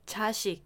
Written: 자식

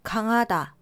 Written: 강하다

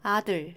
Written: 아들

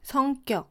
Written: 성격

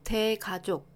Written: 대가족

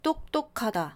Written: 똑똑하다

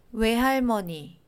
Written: 외할머니

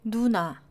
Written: 누나

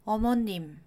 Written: 어머님

